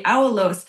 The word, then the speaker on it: aulos: If there was instrumentation, I gather it might have been an instrument called the aulos. Am I saying that correctly? – Yes, you are, the aulos.